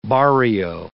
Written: barrio

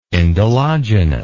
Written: indologenous

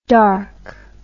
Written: arc'd

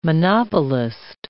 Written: monopolist

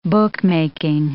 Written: bookmaking

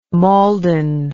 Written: mauldin

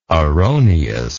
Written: erroneousness

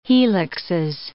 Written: helixes

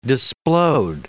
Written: displode

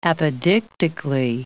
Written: apodictically

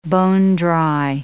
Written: bone-dry

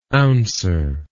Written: ouncer

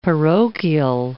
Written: parochial

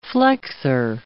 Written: flexor

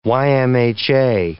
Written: YMHA

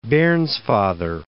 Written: Bairnsfather

